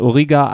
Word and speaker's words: Origa: Und wer jetzt ganz genau aufgepasst hat, dem ist aufgefallen, dass Origa eigentlich nichts anderes als die japanische Aussprache von Olga ist.